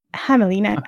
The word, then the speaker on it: Hamelin: Scarlet is based off the Little Red Riding Hood, and Hamelin from Pied Piper of Hamelin. I hope I didn't butcher that. – No, you nailed it.